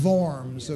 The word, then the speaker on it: Worms: 1521, he was summoned by Emperor Charles V to Worms, pronounced Worms, okay? Yeah. It's not the Diet of Worms.